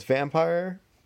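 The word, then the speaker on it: Vampyr: So it's Vampire, but they took out the E at the end and replaced the I with a Y. So it's like Vampire, but you know, the end is pronounced like pyre instead of whatever.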